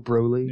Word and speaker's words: Broly: Now it's for sure short O Broly.